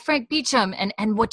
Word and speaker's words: Beauchamp: But it's like spelled like Beauchamp, but it's Beecham.